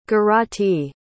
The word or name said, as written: garati